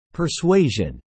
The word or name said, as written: persuasion